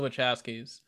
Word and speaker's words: Wachowskis: I wanted to make sure I said it right. The Wachowskis, right? – Yeah, it's the Wachowskis.